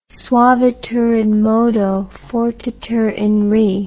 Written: suaviter in modo fortiter in re

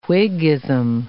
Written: Whiggism

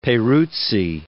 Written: Peruzzi

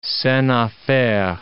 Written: scene a faire

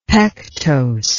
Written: pectose

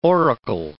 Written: oracle

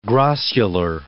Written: grossular

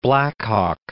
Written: Black Hawk

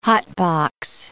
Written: hotbox

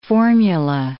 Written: formula

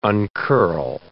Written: uncurl

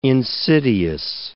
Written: insidious

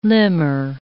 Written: limner

